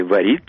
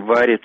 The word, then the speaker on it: варит: Раньше говорили «варит», «солит», «дарит», а мы теперь говорим «варит», «солит» и «дарит».